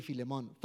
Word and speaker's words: Philemon: Is that how you say it? Philemon. Man, like I'm struggling with that, pronouncing that book of the Bible. I would say Philemon.